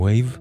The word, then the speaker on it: Waeve: החדש של The Wave, שאת שמם כותבים W-A-E-V-E, e, אבל מבטאים Wave, כמו גל.